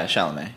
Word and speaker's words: Chalamet: I love the lead actor, Chalamet. – Chalamet. – That's how you say his name.